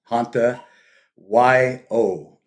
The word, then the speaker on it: Hanta Yo: H-A-N-T-A, Hanta, Y-O, separate word.